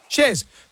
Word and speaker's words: Chers: Shers or Shers? – Shers. – Because girls here have written your name as C-H-E-R-S. Is that how you spell it? – That's how you spell it.